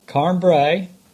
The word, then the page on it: Carn Brea: English